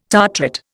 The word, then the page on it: tartrate: Indian